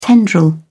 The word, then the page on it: tendril: British English